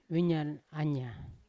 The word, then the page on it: vinyan anya: Yawa